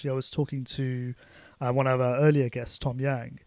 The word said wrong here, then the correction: Tom Young: and Tom Young, as his name is actually pronounced.